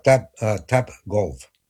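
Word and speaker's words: Topgolf: 88, la calle se llama Tap Golf, pero se escribe T de Tomás, O de Orquídea, P de Pulido, G de Gato, O de Olivia, L de López, F de Feo.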